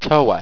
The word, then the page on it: towa: owstwecian